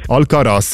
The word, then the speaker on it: Alcaraz: Alvarez. – Alcaraz, ja.